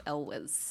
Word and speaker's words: Elwes: It's Elwes. – Yes. – I looked up how to say it because I've heard a lot of people say it very different ways. And okay. I'm like, no. I always used to say Elwes. That's how I pronounced it, but it's Elwes.